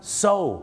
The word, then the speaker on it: sew: so ถูกต้องแล้วครับ SEW ออกเสียงว่า so ครับ so